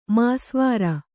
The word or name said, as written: ಮಾಸ್ವಾರ